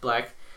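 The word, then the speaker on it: 6LACK: It's Six Lack, but it's pronounced Black.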